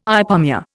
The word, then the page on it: ipomoea: Indian